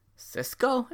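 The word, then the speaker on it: Sisqó: We have the Thong Song, originally performed by Cisco. Is that how it's pronounced? – Yes.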